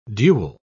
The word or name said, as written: dual